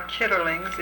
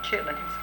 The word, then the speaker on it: chitterlings: The chitlins are chitterlings, if you want to use the pronunciation on the way it's spelled, but we usually call them chitlins.